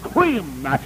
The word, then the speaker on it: Creme: Benjamin Krim, C-R-E-M-E. It's like cream, but you pronounce it Krim.